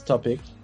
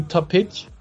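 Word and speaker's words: Topić: Like, my name, my family name is Topic, but it's actually Topic, because my dad's from Croatia, and in Croatia, the C is pronounced like a sh.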